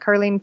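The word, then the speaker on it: Karlene: It's K-A-R-L-E-N-E. Pettit is P-E-T-I-T-T. And I'm also on Twitter at Carlene Pettit, and I love people emailing me questions.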